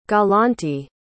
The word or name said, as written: galante